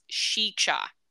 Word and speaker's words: Sica: Now, I've always heard it pronounced Sichi Hollow, but when you look it up how to pronounce it on Google, it says Sika Hollow because it is spelled S-I-C-A. But I think that's more of the phonetic sounding. However, if you look up state park information and you're reading about the state park, it has the like phonetic pronunciation and parentheses, and it says it's pronounced Shicha.